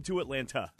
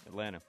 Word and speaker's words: Atlanta: How do you say Atlanta? – Atlanta. – See, he says just like I do. – The Atlanta Braves. – No, I heard a T in there Atlanta.